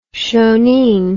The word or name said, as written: shoneen